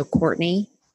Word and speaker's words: Cortney: So my name is spelled C-O-R-T-N-E-Y. So Courtney.